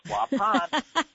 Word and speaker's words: Waupun: He's checking in from Waupun. Please tell me I'm saying it right finally. Am I saying it right? Waupun. – Yes, you are. It's Waupun.